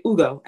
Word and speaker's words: Ugo: Like starting from a young age, like I would hang out with my cousins and they would make fun of the way that I pronounce my name. Like I say Ugo and they were like, you pronounce your name like a white girl, you know?